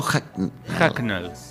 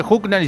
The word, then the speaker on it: Hucknall: Pronunciame de nuevo Hucknall. H-U-C-K-N-A-L-L. Se escribe Hucknall y se dice Hucknall.